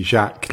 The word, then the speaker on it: Jacques: Is it Jack, like with a soft J, or is it a French Jacques? – It's supposed to be Jacques, like the French way, but nobody ever bothers to pronounce it correctly. So it's good. Call me what you like. Jacques's fine. If you can be bothered to pronounce it correctly, that's how it's pronounced.